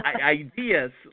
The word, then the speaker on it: IDIS: IDIS, like idea. – Right. So IDIS, yes. it's IDIS Authentic Living Magazine, and IDIS does sound a lot like ideas. And IDIS represents a warrior woman, goddess of the island, and the giver of good fortune and gifts.